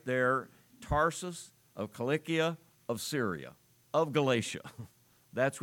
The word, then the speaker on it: Cilicia: And then up at the top, you take a little circle out of Syria and you have Cilicia. I pronounce it that way because in Greek, there are two kappas or two Ks, and in English we usually pronounce those hard sounds, Cilicia, but it doesn't matter.